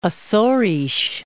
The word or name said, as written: Acores